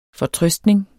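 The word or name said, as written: FORTRØSTNING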